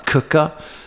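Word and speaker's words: Quooker: Okay, this podcast is sponsored by Cooker. It's spelt Quicker, but pronounced Cooker.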